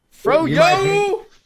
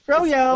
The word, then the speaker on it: Froyo: You're not saying it right. You have to do it with flair. – Froyo! – Froyo. – Like YOLO.